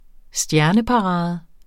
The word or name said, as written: stjerneparade